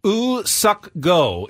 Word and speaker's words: Woo Suk Go: Woo Suk Go. His first name, his given name is Woo Suk, and then his family name is Go.